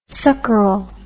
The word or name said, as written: suckerel